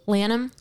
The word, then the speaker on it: Lanham: I never know how to say his last name. It's I'm gonna ask you how you would pronounce it. L-A-N-H-A-M. – Lanham. – Lanham. Is mm-hmm. that what we're going with? – Yeah.